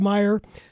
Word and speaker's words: Mayer: Talking to Brad Sturdy from Illini Guys, both of us, both Lauren and I, this morning have said Matthew Meyer's name, Mayer, a time or two. – Have I done that again? – Well, I think I did it too once or twice, but it's spelled Mayer, pronounced Meyer.